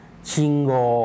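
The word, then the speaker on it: ชิง โก (信号): ต่อไปเราไปดูคำศัพท์นะครับที่เกี่ยวข้องกับการจราจรนะครับเป็นคำๆกันดูบ้างนะครับเริ่มจากคำแรกนะครับชิงโกชิงโกคำนี้ออกเสียงช้าๆชัดๆนะครับออกเสียงว่าชิอึโกอุตรงชิงนะครับคนญี่ปุ่นออกเสียงรวบยาวชิงชิงแล้วโกอุนะครับคนญี่ปุ่นก็ออกเสียงรวบยาวเช่นกันนะครับ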